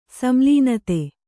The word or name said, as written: ಸಂಲೀನತೆ